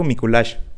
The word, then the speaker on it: Mikulax: En el idioma maya quiché, la correcta pronunciación de la letra X es SH. Yo me pasé todo el fucking episodio diciendo Mikulax cuando debía haber dicho Mikulash.